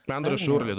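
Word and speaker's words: André Schürrle: Андре Шурли, да.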